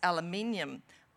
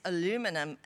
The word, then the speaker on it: aluminium: In Australia, Susanna, we say Miss Aluminium, but I notice in America you say Miss Aluminum, and I love that more luminous pronunciation of the word, so I will try to refer to it as that, and your most recent novel, The Lost Wife.